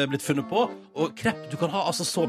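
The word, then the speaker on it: crêpe: Eh, og eh, det som er gøy, er at det området her er kjent for å være opprinnelsen til krepp. – Nei! Oi! Eller er det sånn det uttales? La oss høre. Krepp. Ja, ja.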